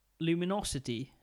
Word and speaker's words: Lumino City: Walked around the corner again, and there was a game that immediately caught my girlfriend's eye, as did mine, called Luminosity, but it's spelled like postcard. – Lumino City.